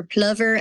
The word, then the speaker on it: plover: It has the island itself, a little plover bird or plover as they pronounce in England, marum grass, the ocean, a bunny, and then of course, St. Aidan himself.